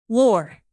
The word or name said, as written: lore